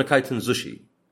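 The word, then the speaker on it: كايتن زوشي (回転寿司): يسمونه كايتن زوشي. ففي بعض الكلمات اللي لا للنطق، يعني زي الإدغام عندنا وكذا.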